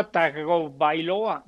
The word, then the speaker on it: Tagovailoa: Wow, pues no pinta nada bien eh, el debut para Tua Bailoa. ¿Ya aprendiste a pronunciar el apellido? – Ya Tua Tagovailoa. Bailoa. – vamos, ahí vamos.